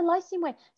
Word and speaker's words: Lycian Way: And then at the end of 2019, you finished out the year by walking the, is it pronounced Lycian Way in Turkey? – Yeah, the Lycian Way.